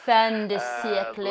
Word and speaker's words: fin de siècle: fin de siècle? How do you say it, pronounce it? – Fin de siècle. Lifestyle.